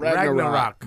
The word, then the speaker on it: Ragnarok: I saw the Ragnarok. What is how do you say that? – Ragnarok.